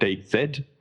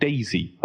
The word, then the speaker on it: DayZ: The letter is Z. It is pronounced Z, so I'm calling it DayZ, because the alternative is to call it Daisy, and I'm not going to do that.